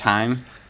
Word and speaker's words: thyme: and ground thyme. Is that how to pronounce it? – Mm-hmm.